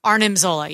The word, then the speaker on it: Arnim Zola: Arnim Zola, yes, I do know that that's how you say it.